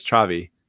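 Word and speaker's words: Xavi: You pronounce his first name Chavi. And I learned that one over in Spain because one of my professors spelt his name that way and it's Chavi.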